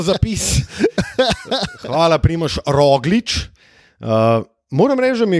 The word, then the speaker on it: Roglič: tako kot Primoš, ampak se pa njen priimek izgovarja z oskim ojem, ne ro, ampak ro, roglič.